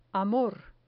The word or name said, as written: amor